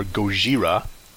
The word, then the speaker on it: Gojira: Well, you watched both, but there was an original re-release of Godzilla, or Gojira, which is Japanese pronunciation, that was, I guess, released for its 50-year anniversary in 2004.